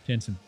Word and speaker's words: Jensen: Assistant Alex Jensen. That's a hard J. Jensen.